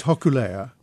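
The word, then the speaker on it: Hokulea (Hōkūleʻa): It's Hokulea, which is the Hawaiian word for Arcturus, that very bright star we see in the sky.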